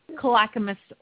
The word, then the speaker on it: Clackamas: Our competitor is from, she's going to have to help me, Clackamas, Oregon? How do you say Clackamas. – That? – Clackamas. Yeah, Clackamas, Oregon.